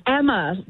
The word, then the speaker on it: Emma: Oh, okay, Emma, like hammer. Is that how you say your name? – Donna, it's Emma. Yeah, pronounced like hammer, but no H.